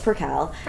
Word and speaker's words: percale: but it's a hard C. – We get percale. – We get percolate. We get percale. We get all sorts of things. So you are not alone. It's percale.